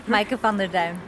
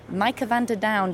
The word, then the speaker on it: Maike van der Duin: How how's the pronunciation on that? Maike van der Duin. Maike, I should say it more with a smile. Maike van der Duin does that sound?